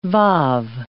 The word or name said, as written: waw